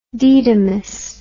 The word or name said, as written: didymus